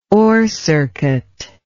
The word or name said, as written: orcircuit